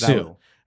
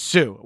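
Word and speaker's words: Hsu: and Stephanie Hu in Everything Everywhere All at Once. Did I say her name right? – I think it's Sue. – It's Sue.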